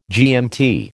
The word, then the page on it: GMT: American English